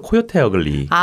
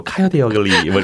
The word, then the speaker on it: 코요테 어글리: 한국 발음으로 이렇게 제가 읽을게요. – 네네. – 제 편에서 코요테 어글리. – 아, 코요테 어글리. 예. 아, 편하네요. – 예. 뭐 카요테 어글리 뭐 이렇게 하실 수도 있겠지만.